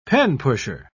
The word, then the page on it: penpusher: British English